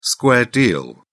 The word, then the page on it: square deal: British English